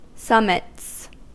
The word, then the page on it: summits: American English